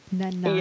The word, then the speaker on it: Nanaimo: And is um, what's the name of the little like I don't know how to pronounce it, Nemo or Nanaimo. Nanaimo.